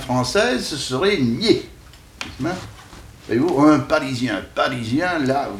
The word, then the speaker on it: Nied: Et puis, il y a aussi deux rivières de Lorraine qui doivent se prononcer nier. – Non, je ne crois pas. – Qui s'écrivent N-I-E-D, mais qui ne sont en pays de langue française, et les gens du pays disent nier. Mais, alors, ils vous disent même, vous prononcez ça comme pied.